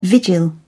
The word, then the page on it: vigil: British English